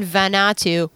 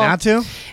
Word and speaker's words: Vanuatu: Vanuatu, which I don't know where that is.